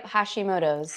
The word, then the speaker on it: Hashimoto's: Yep, Hashimoto's.